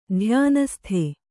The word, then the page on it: ಧ್ಯಾನಸ್ಥೆ: dhyānasthe